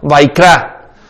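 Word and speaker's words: Vayikra: El tercero se llama Levítico, que significa y llamó, y se pronuncia en hebreo Vaikra.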